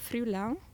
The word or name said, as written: frioulan